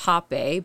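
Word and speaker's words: rapé: Technically, it's pronounced hape, but it's spelled R-A-P-E in Brazil because the H and the R's are switched. So they okay. say the R's with H, so it's like hape.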